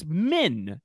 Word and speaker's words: Min: So there is someone here at this inn whose name rhymes with inn. It is Min is the